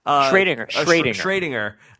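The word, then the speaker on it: Schrödinger: Schrodinger.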